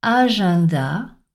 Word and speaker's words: agenda: Alors, EN se prononce souvent en, mais dans quelques mots, on l'entendra prononcer un. C'est le cas dans les mots examen, agenda ou encore référendum.